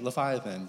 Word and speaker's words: Leviathan: Leviathan? Thank you, Leviathan.